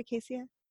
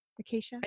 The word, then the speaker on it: acacia: Did I say that right? Acacia? Acacia? – Yeah, I think so.